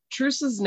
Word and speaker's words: Truus: And Truus's name is spelled T-R-U-U-S. These are Dutch names. So I listened and I think it's pronounced Truus. So that's kind of what I'm going to go with.